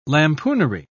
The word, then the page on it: lampoonery: British English